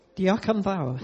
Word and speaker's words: Diolch yn fawr: Diolch yn fawr.